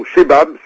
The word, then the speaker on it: Shebab: y compris la milice radicale Shabab ou Shebab, selon comment on le prononce.